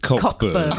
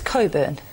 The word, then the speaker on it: Cockburn: Okay, spelt Cockburn, pronounced Coburn.